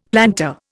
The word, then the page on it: plantar: Indian